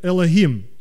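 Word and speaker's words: Элохим (אֱלֹהִים): Но это слово Боже звучит как Элохим. Кто-нибудь слышал такое слово? Мы по-русски говорим Элохим, просто хэ произносим. Во многих языках хэ просто выдыхается. Элохим.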